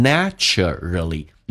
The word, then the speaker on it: naturally: Naturally. 有两种发音，英式发音可以发成 naturally，美式发音那个 a 可以不发，变成 naturally， naturally， naturally，哎，这个发音饱满一点， naturally，自然的 share，打开口腔一点点，然后卷舌头体验一下 share your joy， others will naturally.